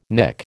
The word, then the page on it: Nick: American English